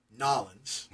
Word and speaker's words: New Orleans: And, you know, that leads us pretty directly into what's coming for WrestleMania 30 in New Orleans. – You mispronounced that. – Excuse me, in Nolens.